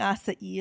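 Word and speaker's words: Açaí: Acai. – Oh my god! Or he didn't believe me. That's how you say it.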